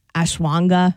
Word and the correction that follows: ashwagandha: No, ashwagandha.